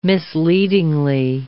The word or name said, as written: misleading